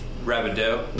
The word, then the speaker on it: Rabideau: Rabideau. I've been guilty in the past of... – Rabideau? – Yeah.